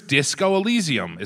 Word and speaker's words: Disco Elysium: Here's Disco Elysium.